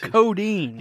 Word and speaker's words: codeine: Yeah, coding. – Okay. – If that's a thing, right? – Coding? – Coding? – Oh, codeine. – Yeah. – No, not codeine. – I was saying it wrong.